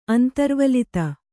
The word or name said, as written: ಅಂತರ್ವಲಿತ